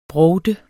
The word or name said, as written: BROVT